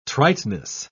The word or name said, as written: triteness